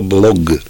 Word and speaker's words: блог: авторы какого-то словаря включили в него слово «блог». Ну, я произношу неправильно, но «г» на конце.